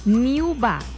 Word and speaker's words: Niuba: Niuba. Como uva, pero con new.